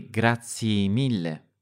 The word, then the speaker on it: grazie mille: You sometimes say grazie mille. So grazie, instead of fully pronouncing the ye ending.